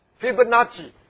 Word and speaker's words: Fibonacci: Fibonacci.